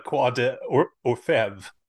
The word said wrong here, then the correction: Quai des Orfèvres: Quai des Orfèvres.